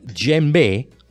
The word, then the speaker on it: djembe: Ecco, ora parlami invece di questo tamburo magico, adesso lo pronuncerò sicuramente sbagliato, il djembe. – Sì, hai pronunciato giusto.